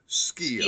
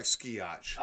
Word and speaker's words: Skio: How do you pronounce his last name again? – Skio. – Skio, okay. – I know it looks like Skiotch.